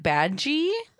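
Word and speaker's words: Badguy: His name is Dominic Badgie, which is spelled like bad guy. – Yeah. But it's French and it means good guy.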